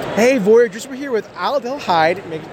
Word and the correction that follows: Aldehyde: Thank you for telling me how to pronounce that. Was that right? Aldehyde. Okay, I'll get that right. I'm sorry about that. But we're here with Aldehyde from AEG Games.